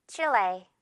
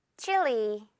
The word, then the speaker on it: Chile: Chile. 전혀 어렵지 않죠? 우리가 하는 방식 거의 그대로. 그리고 이번에는 이 발음으로 해볼까요? 따라해 주세요. Chili.